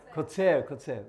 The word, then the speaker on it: קוצר: Zorea Mila the Kote, how you say it? Kotzer, that's the word.